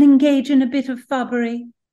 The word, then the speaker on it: fubbery: Fubbery means a deception,